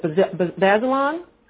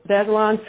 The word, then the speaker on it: Bazelon: In January 2009, Lydia was nominated to serve on the Leadership 21 Committee, an effort by the, tell me how to pronounce this correct, Lydia, Bazelon? – Yep, that's right. – Or Bazelon Center for Mental Health Law,